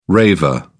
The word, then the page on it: raver: British English